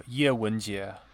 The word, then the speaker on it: Ye Wenjie: Yeah, so Ye Wenjie.